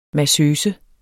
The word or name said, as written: massøse